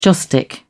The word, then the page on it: joss stick: British English